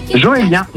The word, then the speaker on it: Joélien: Ça se prononce comment ton prénom? – Joélien. J-O-E-L-I-E-N.